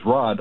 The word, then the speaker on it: Rodd: I'm calling to say my name on my birth certificate is Rod, R-O-D-D, and I always tell people when they ask me if that's short for something else, I say no.